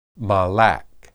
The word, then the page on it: malak: Hebrew